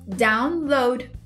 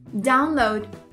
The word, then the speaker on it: download: Então, o download, a forma mais correta de pronunciar é download. É o mesmo acertozinho no load. Download.